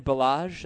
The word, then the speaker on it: Ballage: You have Kalen Ballage, that's how we pronounce it? – Mm-hmm.